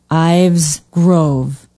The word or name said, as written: ives grove